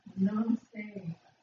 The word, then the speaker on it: Namaste: Namaste. – Namaste, whatever it is, some kind of a vegan thing or something. Yoga, she said. Okay, so it's yoga. Hello, and huh? – Namaste.